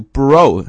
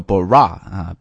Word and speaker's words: bro: 在这里呢，我们一定要提一个单词的发音，就是这个 brother, 啊、兄弟这个词的简读应该读 bro, okay? O 这个音 bro, don't pronounce it like a bra, bra is something different, 啊、是不一样的意思，对吧？